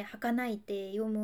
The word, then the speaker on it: 儚い: 人弁に夢って書いて儚いって読む